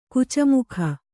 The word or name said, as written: ಕುಚಮುಖ